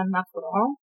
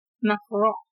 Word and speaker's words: macaron: I want to make chocolate banana macaron, but... – How do you say that? – Macaron. – Macaron. – Oh, come on. You're Portuguese.